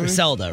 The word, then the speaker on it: Gricelda: G-R-I-C-E-L-D-A. – Yeah, that's probably. – Griselda, right? – Yeah.